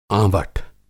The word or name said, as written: आँवठ